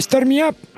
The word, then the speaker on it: Start Me Up: Un clásico maravilloso, el Start Me Up, por ejemplo, se dice así. – Sí, perfecto. Sí.